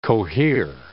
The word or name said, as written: cohere